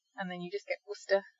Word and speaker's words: Worcester: Okay, number three is this group of letters C-E-S-T-E-R. So if you see this, you just pronounce the S-T-E-R, so it's a st sound. So we've got Worcester. It's not Worcester, it's Worcester.